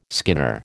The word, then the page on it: skinner: American English